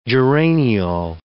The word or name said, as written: geraniol